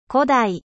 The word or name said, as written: 古代